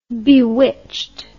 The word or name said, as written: bewitched